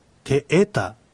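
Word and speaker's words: テエタ: そしてアクセントは二つ目のえにあるてえた、てえたじゃなくててえたとなるわけです。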